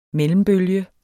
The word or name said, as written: mellembølge